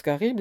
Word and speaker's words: Charybde: Charybde se prononce Charybde et Scylla, Scylla.